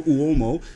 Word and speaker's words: Uomo: So is it Uomo? – Yeah, well, it's Uomo, but most people say Uomo.